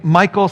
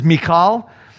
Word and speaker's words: Michal: Sometimes I'll pronounce her name Michal, sometimes I'll pronounce it the Hebrew way, which is Mikal.